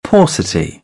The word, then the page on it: paucity: British English; ɔː